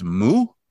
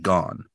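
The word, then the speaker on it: mu (木): Also, wood would be pronounced mu, not gone.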